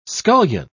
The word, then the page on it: scullion: British English